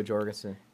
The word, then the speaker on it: Jorgensen: So, the pool that I see is, Biden, Trump, Jorgensen from the... I'm sorry if I said that wrong. Joe from the Libertarian. – Yeah, Joe Jorgensen.